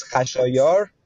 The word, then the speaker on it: Khashayar: It is Khashayar, it, it with a K in it.